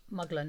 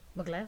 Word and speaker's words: Mugler: Muggler, not staff. – Mugler.